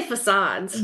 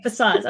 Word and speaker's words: facades: I would say facades. – That's what I would say. Okay, facades. Okay, so that's what I thought. – It looks like facades.